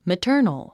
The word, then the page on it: maternal: mətə́ː r nl; マァタァノォ